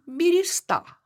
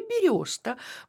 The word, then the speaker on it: береста: Мы сегодня говорим «береста», хотя раньше правильным было только произношение «береста».